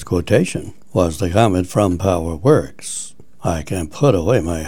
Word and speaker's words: PowerWerx: It's spelled P-O-W-E-R-W-E-R-X. Power Works now offers permanently bonded red slant black Anderson power pole sets.